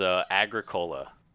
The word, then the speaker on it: Agricola: Not the Grick. It is Agricola.